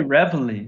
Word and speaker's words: Reveille: It's Reveille. That's how you say it, right? That's how you say the word? – I say Reveille. – Reveille? Okay. Is it Reveille? – Yeah, but that's sort of the, you know, that's the American English pronunciation of what the bugle blasts first thing in the morning.